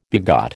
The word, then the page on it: begat: American English